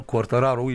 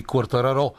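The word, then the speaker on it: Quartararo: Да, и носителя на титлата от миналата година в MotoGP, Фабио Куартараро, или както биха го наричали французите, защото той се състезава за Франция. А французите, както знаеш, послагат ударение само където на тях им е угодно, независимо, че това има италианско, те ще биха го наричали Куартараро.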